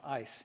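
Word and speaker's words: ice (εἰς): It's E-I-S, but it's pronounced ice.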